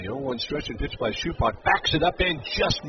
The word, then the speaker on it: Supak: Last name is S-U-P-A-K. It's pronounced Schupach.